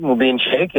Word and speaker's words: Mubin Shaikh: Mubin Shaikh, yeah.